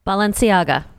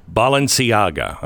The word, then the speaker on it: Balenciaga: Balenciaga. – Balenciaga.